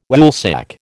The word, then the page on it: Woolsack: American English